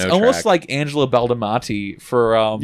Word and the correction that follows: Badalamenti: Also, I know I butchered his last name. – Badalamenti. – Badalamenti, there we go.